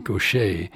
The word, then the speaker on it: Gauthier: We have a track by Duffy called Mercy, by Yasandur called Mercy, and Mercy Now by Mary Gaucher. And it's spelled G-A-U-T-H-I-E-R. So it's not pronounced like it's spelled.